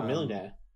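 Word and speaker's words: Mjölnir: I feel like some of the fight scenes were quite satisfying, especially with Natalie Portman of Majorna. Is it Majorna? Mjolnir? I forgot how to say the name of the hammer. – Oh, Mjolnir.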